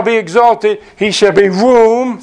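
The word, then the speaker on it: room (רום): In the Hebrew, what we just quoted from Isaiah 6, verse 1, the Lord sitting on a throne, high, it's the Hebrew word room, you would pronounce it room, like we're sitting in this big room, right?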